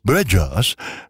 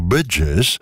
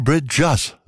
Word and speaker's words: Bridgers: also Bridges, nein, Bridgers, so Phoebe Bridgers, so heißt sie. Wir wollen,